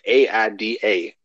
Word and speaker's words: Aida: Not like Ada, but it was like AIDA. I think she pronounced like Aida.